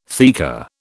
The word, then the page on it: theca: British English